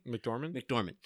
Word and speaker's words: McDormand: McDormand.